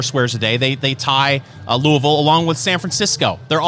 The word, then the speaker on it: Louisville: Next on the list, Louisville.